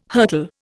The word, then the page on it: hurtle: Indian